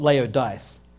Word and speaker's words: Laodice: It was founded about 250 BC by Antiochus of Syria and was named after his wife Laodice. L-A-O-D-I-C-E. So I'm sure it's not Laodice. I don't think you pronounce it Laodice, but Laodice.